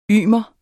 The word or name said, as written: ymer